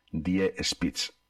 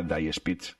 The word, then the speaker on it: Die Spitz: Die Spitz, así en alemán. Aunque ellas juegan con el nombre, porque Die Spitz es algo así como la punta en alemán, pero juegan con que en inglés se pronunciaría Die Spitz, o sea, la muerte escupe o algo así.